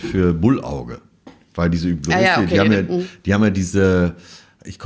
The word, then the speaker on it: Hublot: Und zwar von der Luxus, absoluten Luxusuhrenmarke Hublot. – Also die alle Hublot aussprechen? – Ja, genau.